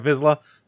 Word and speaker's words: Vizsla: And he was in the Clone Wars cartoons as Pre Vizsla, or however you say his name, Vizsla. – Oh, yeah, Pre Vizsla. – Yeah, Vizsla.